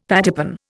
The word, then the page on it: pattypan: Indian